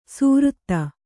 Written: ಸ್ವರಿತ

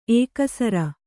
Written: ಎಕ್ಕಸರ

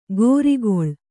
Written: ಗೋರಿಗೊಳ್

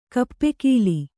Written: ಕಪ್ಪೆಗೋಲು

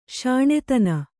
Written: ಸನಾತನ

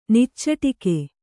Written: ನಿಚ್ಚಟಿಕೆ

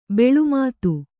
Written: ಬೆಲ್ಮತ್ತಿ

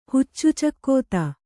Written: ಹುಚ್ಚುಚಕ್ಕೋತ

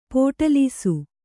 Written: ಪೋಟಲೀಸು

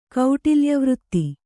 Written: ಕೌಟಿಲ್ಯವೃತ್ತಿ

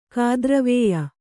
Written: ಕಾದ್ರವೇಯ

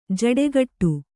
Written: ಜೋಡುಗೂಡು

